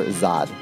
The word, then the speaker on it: Zod: It's pronounced Zod.